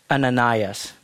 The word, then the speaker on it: Ananias: Another scene going on is in Damascus, there was a disciple named Ananias. Did I pronounce that correctly? Yep.